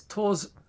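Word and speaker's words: Tórshavn: No, there's a town called, and it's spelt T-O-R-S. – Okay. – H-A-V-N. – Okay. – But I know that you don't pronounce the V, you pronounce it as a U instead. – Okay. – So it's Torzan?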